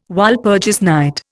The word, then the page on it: Walpurgis night: Indian